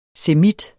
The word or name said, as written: semit